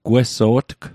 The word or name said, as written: gweso'tg